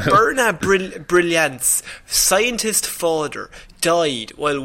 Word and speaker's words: Byrna: I'm struggling with pronouncing her name, but please forgive me. – What's that first name? Sorry. – Birna Brilliant. So B-Y-R-N-A.